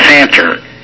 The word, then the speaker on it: Santer: This is a French name, and it's pronounced Jacques, J-A-C-Q-U-E-S, and the last name is Santer, S-A-N-T-E-R.